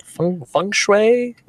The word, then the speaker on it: feng shui: Feng shui? Am I saying that right? – Yeah, it's feng shui is what feng shui. It is. – So, I was right.